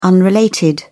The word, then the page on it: unrelated: British English